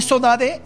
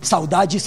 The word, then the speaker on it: sodade: Forma de decir sodade, una palabra que en Brasil conocemos mucho, saudade, saudade, es un término portugués que así se pronuncia en el archipiélago.